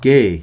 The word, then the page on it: Ke’: Mi'kmaw